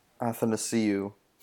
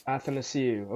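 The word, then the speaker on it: Athanasiou: Andreas Athanasio. How the hell do you know his name? – Athanasiu. – Athanasiu. Okay, there we go. I'll pronounce it correctly from now on.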